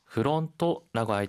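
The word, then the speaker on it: フロント: 하이, 프론토라고 할 때, F 발음이기 때문에, 프가 아니라, 후라고 발음해야 합니다.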